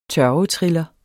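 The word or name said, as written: TØRVETRILLERNES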